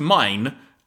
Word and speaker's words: Main: because in the subtitles her name is spelt M-A-I-N, but it's pronounced Mine as in like you're talking German.